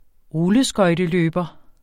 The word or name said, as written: rulleskøjteløber